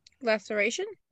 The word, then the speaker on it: laceration: Laceration? – What'd I say? – Lacertation? – Is that a roll, man? Laceration. I did spell it lacertation too.